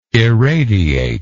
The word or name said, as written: eradiation